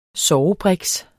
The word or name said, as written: sovebriks